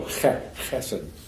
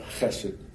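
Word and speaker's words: chesed (חסד): the Hebrew word is chesed. It's guttural, chesed. Don't pronounce it out loud, but to yourself, chesed.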